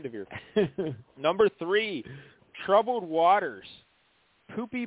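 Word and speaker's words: Poupée: I guess she was born in San Diego, but Poopy, P-O-U-P-E-E, and there's a little line over it, but it's pronounced Poopy. I guess in France, in French, it means doll.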